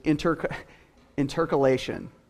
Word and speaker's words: intercalation: The big fancy word for it is intercalation. Yeah, that's how you say it.